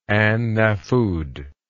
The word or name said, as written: an nafud